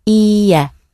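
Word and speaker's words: Ie (いいえ): When you say no, make sure it's a long e. Ie.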